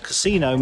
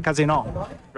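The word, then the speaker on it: casinò: We're on a terrace, the Campari terrace, in front of the Casino, as it's pronounced in Italian, because Casino means shit show Italian. Yeah. So it's very important, the intonation, Casino, rather than Casino.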